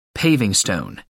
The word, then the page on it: paving stone: American English